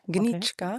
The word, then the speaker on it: Nitschka: Oh ja, am Montag war ich beim Opus 45 mit, ich weiß jetzt, wie der Name richtig ausgesprochen wird, Roman Nitschka. – Nitschka? – Gnitschka. – Okay. – Gnitschka. – Gnitschka.